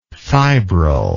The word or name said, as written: fibrillary